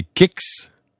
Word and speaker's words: Kicks: Le tout nouveau Nissan, ben, c'est Kicks. – On peut dire Kicks ou Kick. – Kick, ben, c'est parce qu'il y a un S.